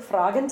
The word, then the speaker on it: fragen: Yeah, so in this case from the verb fragen, to ask. And because of that, in that word then it's a long a sound, and so we keep that in every form, right? – Yeah.